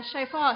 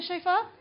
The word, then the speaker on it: shofar: Does anyone think they can blow the shofar? Can try? Shofar. Shofar. Shofar. Okay. I just have my pronunciation corrected.